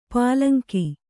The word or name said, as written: ಪುಲ್ಲಿಂಗ